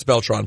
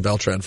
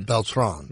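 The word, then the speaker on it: Beltrán: Well, I know it's Beltran properly, but I like saying Beltran for some reason. Carlos Beltran. – That's it. You're supposed to put the accent on the second syllable. – Carlos Beltran. – Beltran.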